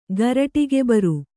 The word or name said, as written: ಗರಟಿಗೆಬರು